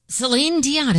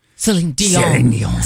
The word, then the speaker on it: Celine Dion: So, Celine Dion is making a comeback. – You said her name wrong. – Celine Dion. Celine Dion is making a comeback.